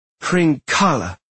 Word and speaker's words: Hringala: No, Hringala. You have to hang on the last syllable a bit.